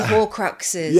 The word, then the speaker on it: Horcruxes: Oh, the Horcruxes.